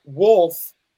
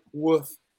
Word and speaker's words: wolf: Wolf, but it's pronounced like woof. – Woof. – Woof. It's like an L. It's like a werewolf.